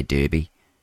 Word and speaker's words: Derby: Derby, they say Derby, don't they pronounce it like that, Derby,